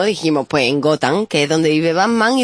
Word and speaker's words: Gotham: Exactamente, que se pronuncia Gotham.